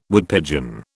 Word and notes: Woodpigeon: British English

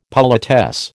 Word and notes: politesse: American English

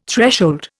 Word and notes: threshold: Indian

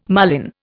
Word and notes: marlin: Indian

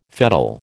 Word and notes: fettle: American English

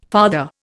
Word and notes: father: Indian

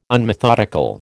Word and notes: unmethodical: American English

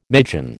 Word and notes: magian: American English

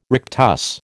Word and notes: rectus: American English

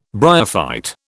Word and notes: bryophyte: British English

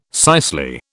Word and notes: cicely: British English

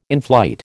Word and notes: inflight: American English

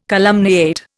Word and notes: calumniate: Indian

